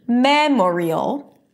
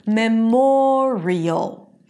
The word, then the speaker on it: memorial: But when we add the -iel suffix, okay, it's not memorial. No, the stress does not remain on the first syllable. It shifts to the one syllable before the suffix, and then it becomes memorial, memorial.